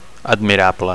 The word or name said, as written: admirable